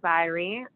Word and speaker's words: Zyrie: So I am Jessica Zyrie, and so you can find me on Facebook with that. My last name is spelled Z-Y-R-I-E. And on Twitter, Instagram, you can find me at the Jessica Zyrie, T-H-E-J-E-S-S-I-C-K-Z-Y.